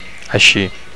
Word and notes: aixi: Catalan

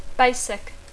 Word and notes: basic: English

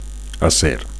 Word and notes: acer: Catalan

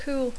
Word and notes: cool: English

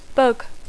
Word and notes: bug: English

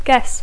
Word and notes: guess: English

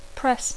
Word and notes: press: English

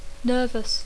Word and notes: nervous: English

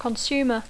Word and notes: consumer: English

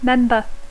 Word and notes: member: English

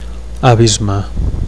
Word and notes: abisme: Catalan

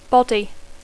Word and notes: body: English

